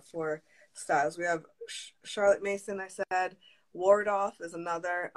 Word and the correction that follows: Waldorf: Wardorf, I have to say it wrong. I think I'm saying Waldorf? – Waldorf. – Waldorf? Okay, yeah. – Waldorf, yeah. Waldorf.